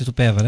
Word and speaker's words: Itupeva: Falei certo, Itupeva, né? – É Itupeva, Itupeva. Foi certinho.